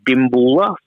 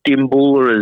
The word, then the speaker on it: Dimboola: So the name of the town is Dimboola. So Dimboola is D-I-M-B-O-O-L-A.